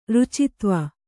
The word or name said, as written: ರುಚಿತ್ವ